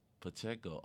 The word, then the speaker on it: Pacheco: Number 10, Pacheco, is that how you pronounce his name? Pacheco. Oh, I was right.